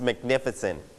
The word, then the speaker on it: magnificent: The sunset on the beach was magnificent. Magnificent. สวยมากๆเลยสวยงามมากนะครับ So you stress on the first syllable, the second syllable, or the third syllable? You stress on the second syllable, magnificent, magnificent.